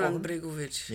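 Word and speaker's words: Goran Bregović: Goran Bregovic, ja.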